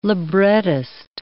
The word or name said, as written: librettist